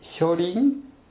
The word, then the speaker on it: 初臨: 最初の初に望むという字を書いて初臨と読みます。